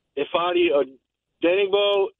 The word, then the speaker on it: Ifeadi Odenigbo: If not, it's some combination of Ifeadi Odenigbo and Dayo Odenigbo.